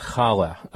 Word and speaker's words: challah: I mean, you know, ideally you're pronouncing it with that ch sound, challah. – Yeah, exactly. – You know, that guttural sound that makes you sound like you're clearing your throat.